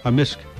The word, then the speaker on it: amisk: Amisk. – Amisk. – Amisk. – So the K can be silent, but generally it's...